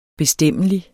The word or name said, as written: bestemmelig